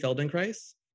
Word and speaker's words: Feldenkrais: She has extensively studied and practices the works of Dr. Moshe Feldenkrais. I pronounced that right? – You sure did, yeah.